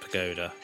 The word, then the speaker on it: pagoda: Get our material back from Yuffie, and it's time to head back into Wutai to take on her father at the Propaganda? I'm going to look up how to pronounce that. Pagoda. It's just Pagoda. I was saying too many letters.